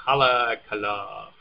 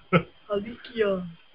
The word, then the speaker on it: Haleakala: So anyway, in Haleakala... – Haleakala. – Yeah, there you go.